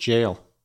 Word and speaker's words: gaol: it is the word jail, right? But it is spelled G-A-O-L, so it looks like gaolers, but I think it might even be pronounced jailers.